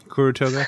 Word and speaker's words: Kuru Toga: How do you say it? Curry toga. – Mm-hmm. Kuru toga. Kuru, kuru toga.